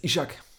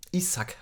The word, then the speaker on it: Isak: Ishak Isak heißt er ja übrigens, ne? Ah, krass. Hat er, glaube ich, gesagt bei seiner Vorstellung, Isak wird er ausgesprochen.